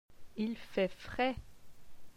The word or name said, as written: Il fait frais